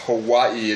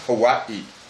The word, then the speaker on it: Hawaii: So basically what you do with every one of the Hawaiian words, after the vowel is where you take a beat. So if you want to pronounce Hawaii, it's Hawaii. – Okay. – All right. And the double I is an elongated E, so Hawaii.